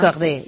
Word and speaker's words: Corday: Hombre, ¿cómo lo has pronunciado, ¿verdad? Corday. Ya Corday. Me has puesto el listón muy alto.